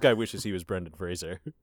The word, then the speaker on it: Fraser: That's how his name's pronounced, man. I don't know what to tell you. I listened to episode 7 of the Scrubs podcast, and he says his name is pronounced Fraser.